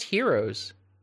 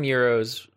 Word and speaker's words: gyros: I thought they're pronounced heroes. I think people call them euros, but if you go to a Greek restaurant, like a real Greek restaurant, they call them heroes. Even though they're spelled with a G.